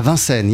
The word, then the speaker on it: Vincennes: And, and I'm, I'm, gonna I know I'm gonna say this wrong. Uh, Vincent. – Ah, Vincennes, yeah. Vincennes, see, I said Vincennes.